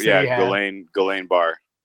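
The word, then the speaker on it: Guillain-Barré: Jan Bear syndrome. Yeah, Travis I can say Travis Frederick. – Travis Frederick. It's the auto, autoimmune disease. – Guillain-Barre.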